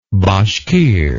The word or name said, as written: bashkir